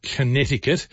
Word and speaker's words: Connecticut: US state of Connecticut actually has got a C in it. So if you're doing it phonetically, it's Connecticut.